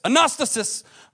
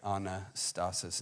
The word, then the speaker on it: Anastasis: Now, if you are Greek, you say it like this, Anastasis, but we're English, or we're American, we speak English, so we're going to say Anastasis and stretch it out a little bit.